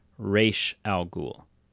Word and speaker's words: Ra's al Ghul: But in many iterations of the character, it is pronounced Ra's al Ghul.